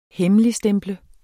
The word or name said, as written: hemmeligstemple